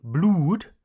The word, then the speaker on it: blood: Sometimes when we have two O's together, it is pronounced oo. But this is not the case with blood. Listen to my pronunciation. I don't say blood, no. It's pronounced blood.